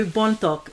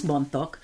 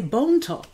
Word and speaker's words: Bontok: Um, and when the Americans came, they couldn't pronounce Bontok. They would say Bone Talk, let's go to Bone Talk.